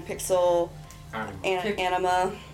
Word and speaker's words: PixelAnima: Say it properly. – Pixel anima. – Anima. – Anima. – Yes. It's just too related to the other word when you say it. So you have to say anima as in anima.